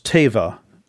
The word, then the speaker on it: teva: Hebrew word is teva, T-E-V-A-H would be pronounced.